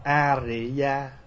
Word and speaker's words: Ariya: Thưa đại chúng, dù là A-li-ya hay A-ri-ya đều là chữ phiên âm từ tiếng Phạn là A-ri-ya. Nhưng mà so ra giữa chữ A-li và A-ri á, thì chữ A-ri nó gần hơn.